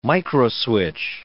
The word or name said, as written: microswitch